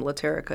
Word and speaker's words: Latarika: The way to remember how to pronounce my name, Latarika, is it rhymes with America.